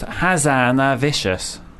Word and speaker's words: Hazanavicius: It actually is pronounced Hazanavicius. Hazanavicius.